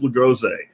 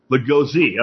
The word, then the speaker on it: Lagoze: So with that, let's go ahead and get our special guest on the show, Mr. Miles Lagoze. And Miles, did I get your name right, sir? – Almost. It's Lagoze. – Lagoze.